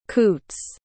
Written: coots

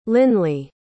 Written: linley

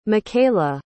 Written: makaila

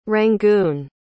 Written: Rangoon

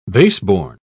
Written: baseborn

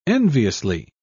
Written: enviously

